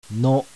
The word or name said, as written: NO